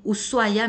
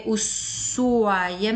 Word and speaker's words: Ushuaia: Eh, Ushuaia, que se escribe así, Allá, estando en Ushuaia, me enteré que se pronuncia Ushuaia, no Ushuaia, ¿vale?